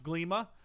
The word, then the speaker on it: Glima: It's pronounced Glima. G-L-I-M-A. Pretty sure.